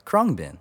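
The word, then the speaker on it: Khruangbin: So, the number one seed in the Eastern Conference is Con Todo El Mundo by Krongbin, which is actually how it's pronounced.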